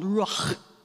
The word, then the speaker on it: ruach: The word is ruah. It's actually, if you pronounce it, this is going to sound lovely in a microphone. If you pronounce it with a Hebrew guttural, it's called ruach. Go ahead and say that with me, ruach.